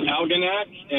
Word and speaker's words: Algonac: It's Algonac, and thank Algonac. – You for taking my call. Appreciate it. – Algonac. – Yes, sir. – Never heard of it. Go ahead. – Algonac, yep.